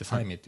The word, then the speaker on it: 西面: 微妙に。微妙な、サイメというところがあるんで、はい、西面って書いてサイメって読むんですけれども、はい、ここで、あ、ブルーベリー農園があるんですね。